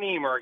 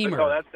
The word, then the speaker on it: Niemerg: Well, it's Adam Niemerg, actually. Niemerg. That's close.